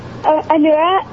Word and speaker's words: Anura: Hello, my name's Anura, and the answer is Big Top. – Spell it again. – Anura, A-N-U-R-A.